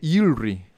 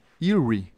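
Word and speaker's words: jewelry: Muchachos, vieron que ya dije Ilri, Ilri. Ya eh, puedo pronunciar decentemente sin que me dé un derrame cerebral, joyería en inglés.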